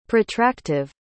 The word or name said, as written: protractive